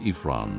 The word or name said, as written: Ephron